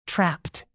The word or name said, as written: trapped